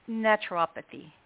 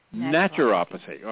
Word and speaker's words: naturopathy: And then really what it is you do as a doctor of neuropathy. Am I pronouncing it right? Others say neuropathy. – Naturopathy. – Naturopathy.